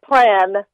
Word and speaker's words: plein: Well, it's a little bit like plan. – Plan. You know, like P L A N kind of. It's a French word, yes.